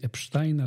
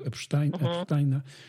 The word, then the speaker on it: Epsteina: Powiedz mi, czy porusza cię to, co dzieje się wokół historii człowieka, którego nazwisko wymawiane jest czasami Epsteina, czasami Epsteina. Ja będę chyba mówił Epsteina.